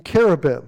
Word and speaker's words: cherubim: So he drove out the man, and he placed at the east of the Garden of Eden cherubim, as it should be pronounced, and no S, and a flaming sword which turned every way to keep the way of the tree of life, to guard it.